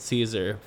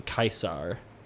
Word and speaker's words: Caesar: I don't know when we started pronouncing it Caesar, but apparently it's actually Kaisar. – Kaisar is way cooler. – Yeah, it sounds more. It's like Kaiser Wilhelm, you know.